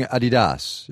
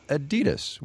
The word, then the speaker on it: Adidas: You've been saying Adidas, as in Adi Dassler, Adidas. Over here we say Adidas. Which one's right? – Oh, definitely the European pronunciation, and it's really strange. I really can't explain why you pronounce it differently in the US.